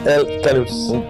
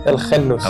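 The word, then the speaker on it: El Khannouss: En om het vanaf nu meteen voor eens en voor altijd juist te doen, hoe spreek je jouw naam eigenlijk correct uit? Bilal El kanus Kanus. En op zijn Arabisch klinkt dat? El Ghanous. El Ghanous. Maar we mogen gewoon Canus zeggen, hè?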